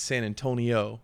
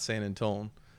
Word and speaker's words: San Antonio: Cause she's like, if they really mean it, it's San Antonio and not San Antone, because it's like, you really want to say San Antonio cause nothing really rhymes with it.